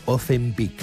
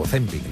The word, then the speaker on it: Ozempic: mucho de Ozempic o Ozempic, no sé cómo se dice, no sé si lo he dicho bien a la primera, ¿es Ozempic o Ozempic? – Las dos válidas.